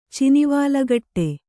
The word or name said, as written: ಚಿನಿವಾಲಕಟ್ಟೆ